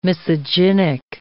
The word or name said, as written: misogynic